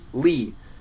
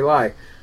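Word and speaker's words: Ly: Her name was Sherry Lee. She was Laotian. Her last name was spelled L-Y, pronounced Lee. Used to piss me off. Everybody would always call her Sherry Lie.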